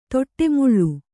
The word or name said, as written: ತೊಟ್ಟೆಮುಳ್ಳು